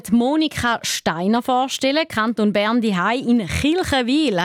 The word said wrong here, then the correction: Kriechenwil: Habe ich das richtig gesagt? – Ja, Kriechenwil. – Kriechenwil.